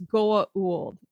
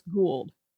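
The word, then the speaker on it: Goa'uld: I decided in this episode, Teal'c says Goa Uld. And I'm like, if anybody on this show knows the proper pronunciation, it's him. So it is definitely not pronounced Gould. It is Goa Uld.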